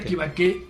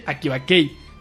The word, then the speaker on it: Akibakei: No se dice Akibakei, sino se dice Akibake. – Ah, okay. – ¿No? Que es otra cosa que también por la forma de leer, es como intentar leer la H, ¿no? – Exactamente. – Es el ahí no se pronuncia, es Akibake.